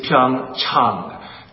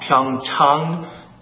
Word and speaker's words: Pyeongchang: The correct pronunciation is Pyeongchang. Chang, ah, ah, like when you go see your doctor, ah. Pyeongchang, Pyeongchang, Pyeongchang.